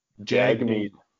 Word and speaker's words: Jagmeet: Shagmeet. – Jagmeet.